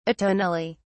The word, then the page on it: atonally: British English